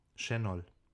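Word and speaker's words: Şenol: Schenol. – Ja. – Wie SCH. – Das S wird wie SCH gesprochen.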